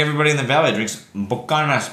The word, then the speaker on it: Buchanan's: But in the valley, it's Buchanan's. But it's pronounced Buchanan's.